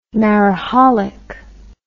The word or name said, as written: mariholic